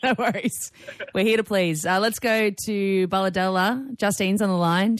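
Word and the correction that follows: Bulahdelah: Did I pronounce it correctly, Justine? – No. – Oh, you tell me. Where are you from? – Bulladella. – Bulladella. There you go.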